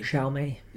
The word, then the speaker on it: Xiaomi: I suggested a company called Xiaomi. If that is how you pronounce it, X-I-A. – Xiaomi.